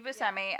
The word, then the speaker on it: Buscemi: Oh, Steve Buscemi. – Buscemi. Buscemi. Yeah, Steve Buscemi.